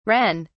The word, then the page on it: ren: British English